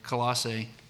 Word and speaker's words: Colossae: Now, some quick background on Colossians, or Colossae. I don't even know if I'm saying that right. Yep.